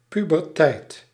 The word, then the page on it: pubertijd: pu·ber·tijd